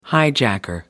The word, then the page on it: hijacker: British English